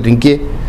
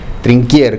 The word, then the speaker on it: Trinquier: Son libros que se manejaban, sobre todo este último, el de Trinquier, se escribe así, Trinquier, se pronuncia Trinquier, Trinquier, coronel Trinquier.